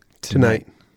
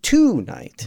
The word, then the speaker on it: tonight: You're putting the accent on the second syllable, mm-hmm. syllable, and that second syllable is like a short staccato night. – Mm-hmm. – So like, don't put the T on the end of it and say it.